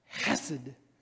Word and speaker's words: chesed: And he says, you kind of got to spit a little bit when you say that word, you know? Kind of little guttural, like chesed is how they say it.